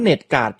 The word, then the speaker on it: NetGuard: หมดจากครั้งที่แล้วพี่ครับแอปพลิเคชันที่บอกเมื่ออาทิตย์ก่อนที่ว่าปิดสวิตช์เน็ตเป็น LINE แอปเนี่ยบน Android เนี่ยมันชื่ออะไรกันแน่อ้อโอเคแอปพลิเคชันที่ผมพูดเนี่ยมันจะชื่อว่า NetGuard นะครับ NET นะครับตัว N Norway แล้วก็ E East นะครับ T Thailand นะครับ Net แล้วก็ G German นะครับแล้วก็ตัว U แล้วก็ตัว AN แล้วก็ตัว R Red แล้วก็ตัว D Dog นะครับอ่านว่า NetGuard